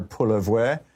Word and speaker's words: Poilievre: Pierre Poilievre, or I guess French-Canadian, you pronounce the R at the end, French, you don't.